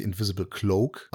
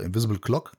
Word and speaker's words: Invisible Cloak: Das heißt eigentlich Invisible Cloak, aber okay. Die okay. sagen einfach Invisible Clock.